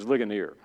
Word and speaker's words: Ligonier: And so I found out about this thing called Lejeuner, which is Ligonier. I mispronounced it.